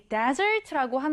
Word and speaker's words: desert: 사막은 desert인데, 후식이나 디저트라는 의미의 단어는요, 뒤에 강세가 들어가서 dessert, dessert. 좀 다르죠?